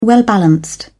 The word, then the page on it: well-balanced: British English